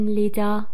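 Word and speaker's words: Lid: یک اسم نویترال یا خونسا هست که حالت جمعش هم با افزودن ار به دست میاد، آوگن لیدا. درباره کلمه لید هم همونطوری که میبینید د رو ت تلفظ میکنیم، لید.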